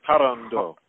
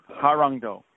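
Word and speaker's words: Hwarangdo: Is a longtime practitioner and grandmaster in the Korean art of, I'm going to mispronounce this, Harangdo. – Harangdo, since 1976.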